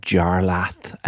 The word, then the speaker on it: Jarlath: And I mean, I can't really complain about Jarlath, a name that is pronounced as it's spelled.